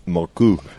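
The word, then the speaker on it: Mørkøv: Yeah, a Danish listener got in touch with the correct pronunciation. Morkov in English, or Morkov, but Moku apparently in Danish.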